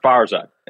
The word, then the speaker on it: Farzad: It's pronounced Farzad. It's a Farzad. Super Persian name.